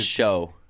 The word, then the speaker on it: Hsu: But I don't think people talk enough about Stephanie Hsu. I think that's how you pronounce it. – I believe it's Show. – Is it Show? – Yeah.